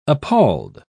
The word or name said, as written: appalled